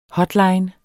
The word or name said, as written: hotline